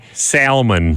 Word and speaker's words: salmon: What's that L doing in there? Nobody uses it. – Well, I mean, salmon. – It's the point of any silent letter.